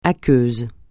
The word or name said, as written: aqueux